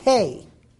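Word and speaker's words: hey (ה): It's the word he. It's pronounced hey.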